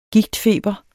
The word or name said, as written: gigtfeber